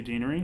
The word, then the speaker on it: Deanery: Deanery.